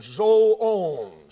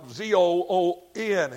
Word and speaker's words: zoon: The word beast, however, the word here comes from the word zoon, spelled like, well, Z O O N in English, along all zoon.